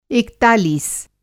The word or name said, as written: एकतालीस